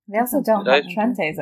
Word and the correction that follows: Trenta: over there. – Oh, I said it wrong, didn't I? Trenta. – Trenta? Yeah, Trenta, with an A.